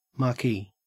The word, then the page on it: marquee: Australian English